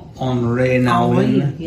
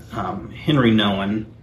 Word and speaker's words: Henri Nouwen: Henri Nouwen. – Yes. – How do you say it? Henry Nouwen is how the redneck would say it.